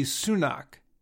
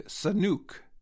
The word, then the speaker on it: Sunak: He had trouble saying the name of Great Britain's new prime minister, Rishi Sunak. Biden said Rashid Sunuk.